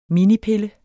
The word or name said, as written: minipille